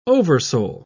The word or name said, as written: oversoul